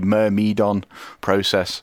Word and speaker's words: Myrmidon Process: You might remember last time I played a track by, I think I said Meridian Process, or I can't remember what I said. But anyway, I pronounced the name horrifically wrong. So I've got the phonetic spelling here, and it should be Myrmidon Process. So, I mean, I wasn't that far away, but I was wrong.